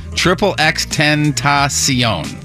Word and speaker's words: XXXTentacion: All right, I've looked it up, and it is officially pronounced, according to the internet, XXXTentacion.